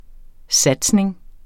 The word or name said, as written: SATSNINGERNE